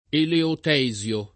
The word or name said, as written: eleotesio